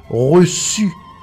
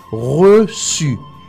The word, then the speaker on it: reçu: Misma cosa si, por ejemplo, tenemos que escribir reçu. Reçu. R-E-C-C-D-U. Reçu. Quita la cedilla, vamos a tener recu. Porque la C-U es Q. Pero con la cedilla es su. Reçu.